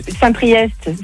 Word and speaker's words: Saint-Priest: Saint-Priest, c'est EST, tu le prononces.